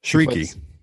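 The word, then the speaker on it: Chriqui: We've got Emmanuel Chirik. – Shrieky. – What? – Heard you. Shrieky is how you pronounce her last name.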